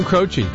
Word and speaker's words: Croce: Is that how you pronounce that last name? – Jim Croce. – Croce.